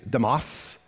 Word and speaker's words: Demas: His name is Demas, D-E-M-A-S. Now, the correct pronunciation is Demas, but I'm a hillbilly from Virginia, so Demas.